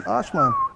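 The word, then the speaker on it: Ajman: And I was, Walter is actually working on a project in Ashman. – Yes. – If I said that correctly. – Ashman, yeah, you got it.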